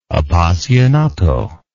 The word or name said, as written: appassionato